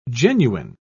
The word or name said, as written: genuine